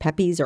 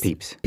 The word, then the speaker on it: Pepys: I don't know if it's pronounced Peppies or Peepies. – It's Peeps.